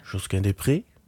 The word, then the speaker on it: Josquin Desprez: Ik weet niet precies, hoe spreek je die uit? – Josquin Desprez. – Desprez.